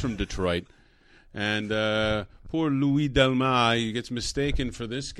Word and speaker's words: Delmas: If you haven't looked at it, Delmas is spelled D-E-L-M-A-S. Lewis is L-O-U-I-S. – Louis Delmas. The S is, if everyone knows who speaks French, the S's are silent in French.